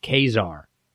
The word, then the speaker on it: Caesar: Was Julius Caesar part of the Roman Empire? – Yes, Julius Caesar was part of the Roman Empire, and actually it's pronounced Kazar.